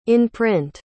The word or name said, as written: in-print